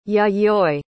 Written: Yayoi

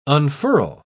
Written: unfurl

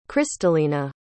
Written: kristalena

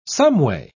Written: someway

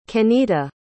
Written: kennita

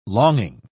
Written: longing

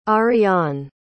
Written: ariane

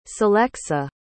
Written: celexa

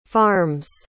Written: farms

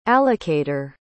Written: allocator

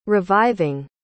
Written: reviving

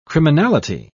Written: criminality

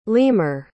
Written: leamer

